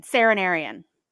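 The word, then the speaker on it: Seren Arian: It's S-E-R, I will spell it for you. S-E-R-E-N, Saren, Arian, A-R-I-A-N, Serenarian.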